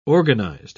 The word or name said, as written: organized